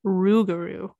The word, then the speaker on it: rougarou: And the way that they pronounce it is rougarou. But anyway, go ahead. – But they have it spelled as L-O-U-P-G-A-R.